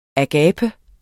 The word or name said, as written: agape